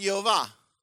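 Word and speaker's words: Yehovah (יְהֹוָה): And the vowels are the schwa, the holom, which is a little point, and the little A, the little A. So it's pronounced Yehovah.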